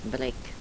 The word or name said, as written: brek